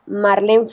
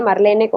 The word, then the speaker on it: Marlene: Ok, ahorita me manejo bastante por Instagram, que es arroba Marlene Franco. Mi nombre se escribe Marlene con E.